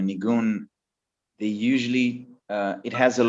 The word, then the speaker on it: nigun: So Negan is in Yiddish and it's meant, it's like... how do you say it? How do you say what Negan is? Nigun. It's like...